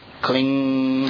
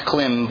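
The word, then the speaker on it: klīṃ: Kling. Now it's pronounced klim, but you don't. I mean, it's written klim, but you don't say that. You say kling, like holding on, right?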